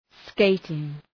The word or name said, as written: skating